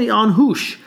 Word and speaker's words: Anhush: How do you say it in Armenian? What's the... – Is it Armenian? Anhush. – Anhush.